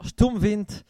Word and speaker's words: Sturmwind: Alors faites attention à la prononciation, c'est d'allemand Sturmwind. Alors les tocards sur le forum qui essaient de prononcer ça à l'anglaise. Quoi Donc, pour la petite leçon, le U se prononce OU et le D en fin de mot en allemand se prononce T.